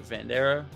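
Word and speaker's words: Vanderaa: That's how you say his name, right? Vandera.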